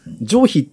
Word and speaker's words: 上皮: はい、うん、ええ、上皮っていうのは、上下の上、上って書いて皮って書いて上皮って読む。